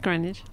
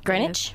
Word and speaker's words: Greenwich: Did I say it right? – It's Greenwich. – Greenwich? Yes. Greenwich. – Yeah. The W's silent, but that's okay.